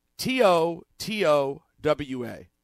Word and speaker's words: Totowa: How do you pronounce T O T O W A? Totowa.